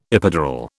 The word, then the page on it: epidural: British English